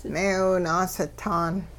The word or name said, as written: meewi-naasitaan